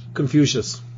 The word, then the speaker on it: Confucius: Yeah, and Confucius himself, how do you say it in English? – Confucius.